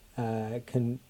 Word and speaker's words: Kondratiev: Kondratiev, thank you, who had this idea that some technologies lead to, as they diffuse through the economy, lead to long-term growth.